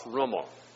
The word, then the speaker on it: Rummel: Some research was done at the University of Hawaii by a man named Rudolf Rummel, spelled R-U-M-M-E-L, but is otherwise pronounced pretty much like our Rummels pronounce their name.